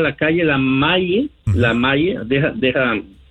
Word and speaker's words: Maie: 7730 Maye, se escribe M-A-I-E, Avenue, Los Ángeles, California, 9001.